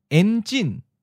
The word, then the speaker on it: Enjin: Enjin. En is in the ninth tone. Enjin is in the second tone. High falling.